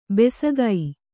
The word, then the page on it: ಬೆಸಕೈ: besakai